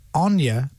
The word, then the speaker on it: Enya: I'm saying that is Enya, not Enya.